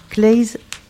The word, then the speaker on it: Claeys: Donc, un film suivi d'un débat sur la fin de vie et les dispositifs de la loi Claeys. C'est comme ça qu'on prononce, Leonetti.